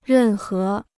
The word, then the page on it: 任何: rèn hé